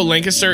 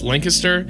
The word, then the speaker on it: Lancaster: First of all, also Ohio Lancaster is pronounced Lancaster. Anywhere else is Lancaster.